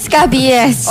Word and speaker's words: scabies: Saya bacanya soalnya tentang babies, babies gitu. Scabies. – Oh, scabies. scabies. – Bukan babies. Oh.